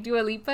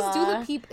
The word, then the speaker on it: Dua Lipa: And like, I love me some Dua Lipa. What is doula peep? Is that doula peep? – Oh, um, it was Wendy Williams. yeah, she didn't know how to say her name.